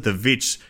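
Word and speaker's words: The VVitch: I'm thinking of like vibes like The Witch, or as many might pronounce it, The Witch with a double V that you can see on Netflix, or The Apostle, which is also on Netflix.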